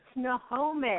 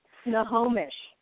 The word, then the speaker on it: Snohomish: Snohomish. – What is it? – There you go. Snohomish.